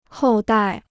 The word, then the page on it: 后代: hòu dài